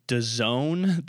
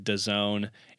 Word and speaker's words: DAZN: That's how they're pronouncing it. But if you read it in print, it's just capital D, all capitals. So it's capital DAZN. So they're, and then they're pronouncing it DAZN.